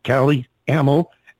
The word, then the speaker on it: Calle Ameln: It's pronounced Calle Ameln.